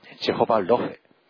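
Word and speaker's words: Jehovah Rophe: そのうちの一つ目がジェホバロフェ、英語の発音で言えばジェホバラファーですけれども、いずれも同じです。ジェホバロフェというふうに、講演で、去年学びましたので、そちらの発音でいきたいと思います。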